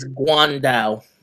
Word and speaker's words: Guan Dao: By the way, it's Guan Dao. – Guan Dao. – It is Guan Dao? – Yes. – That sounds like a Cantonese pronunciation.